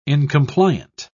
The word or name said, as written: incompliant